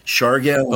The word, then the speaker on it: Siargao: How do you spell Siargao? – It's S-I-A-R-G-A-O. – Okay. – Siargao. – Cool. – Not to be confused with Surigao, which is the city, S-U-R-I-G-A-O. Okay. Same problem. – Okay. – Siargao and Surigao.